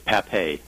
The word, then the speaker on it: Papay: It's Papay. – Papay. P-A-P-A-Y.